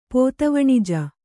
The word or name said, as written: ಪೋತವಣಿಜ